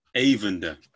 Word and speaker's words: Eyvindur: I want to get your name right, and Evander. – Yeah. – Is that right? – Eyvindr. – Eyvindr. Cool. Got it.